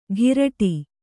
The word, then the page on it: ಘಿರಟಿ: ghiraṭi